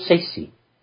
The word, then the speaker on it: Saisae: And the meditation that we do in the spirit world is called Sesi. That is spelled S-A-I-S-A-E. And the A and the E are locked together. – Mm.